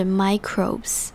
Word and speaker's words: microbes: Well, the water itself doesn't have any sugars or proteins like food, so microbes aren't consuming it. 接着我们来看 microbes 这个字，这个字就是微生物的意思。那要注意的地方是这边的 I 是长母音 I 的声音，而后面的 O 是长母音 O 的发音，所以整个字是 microbes。